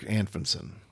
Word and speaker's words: Anfinson: It's Eric Anfinson.